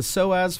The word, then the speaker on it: psoas: If you're listening at home, that's spelled with a silent P. If you want to look it up, it's spelled P-S-O-A-S.